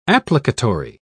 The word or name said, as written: applicatory